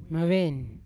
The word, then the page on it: meven: Yawa